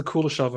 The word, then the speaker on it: Kuleshov: And one of the first things they taught us in film school was the Kuzlov effect. – Yeah, I know it as the Kuleshov effect. – Yes, probably the better way to pronounce it.